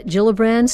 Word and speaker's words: Gillibrand: Senator Kirsten Gillibrand, spelled with a G, pronounced with a J, the Democrat of New York exploring the presidential run in 2020.